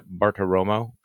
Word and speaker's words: Bartiromo: The president calls into, and correct my pronunciation here, the Maria Bartiromo show.